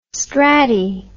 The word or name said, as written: strati